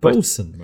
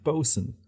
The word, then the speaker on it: boatswain: Bosun, Mark. It's pronounced bosun. – Really? – Yeah, bosun. – Yeah, inexplicably that word is pronounced bosun.